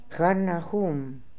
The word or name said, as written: Harnahoom